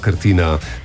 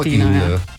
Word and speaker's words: Krtina: Naš tonski tehnik Mitja Groznik je opozoril, ja. Da se Krtina torej izgovori, da gremo na Krtino, na Krtino.